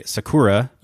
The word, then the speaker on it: sakura: the name for the Japanese cherry in Japan, which is spelled S-A-K-U-R-A. – Yeah. – We might say, you and I might look at that as English speakers and say Sakura, but it's actually Sakura.